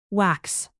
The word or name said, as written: wax moon